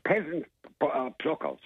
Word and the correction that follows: pheasant pluckers: The pheasant pluckers.